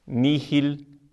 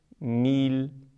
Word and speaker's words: nihil: A segunda palavra, nil, deve ser pronunciada como se o H não existisse.